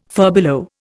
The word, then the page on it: furbelow: Indian